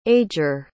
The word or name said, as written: ager